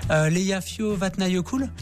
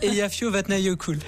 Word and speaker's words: Eyjafjallajökull: En Islande, on survole des déserts de cailloux, on survole des cascades, on a survolé Eyjafjallajökull. – Vous réussissez à prononcer le nom, alors là, chapeau. – Non, mais on a bossé. – Une deuxième fois. – Eyjafjallajökull.